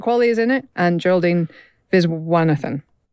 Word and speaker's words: Geraldine: And he has cast Margaret Qualley in it, along with Geraldine Viswanathan. And I hope I'm pronouncing that right. Apologies. – Geraldine is correct, yes.